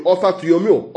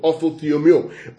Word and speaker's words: orthotomeo: I'll spell that word for you. Orthotomeo. Orthotomeo. O-R-T-H-O-T-O-M-E-O. I'll spell that again. O-R-T.